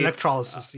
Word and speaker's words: Electrolysis: So, what Firefox Quantum contains, it has Electrosys, right? If I'm pronouncing that correctly. – Electrolysis, yeah. – It's Electrolysis.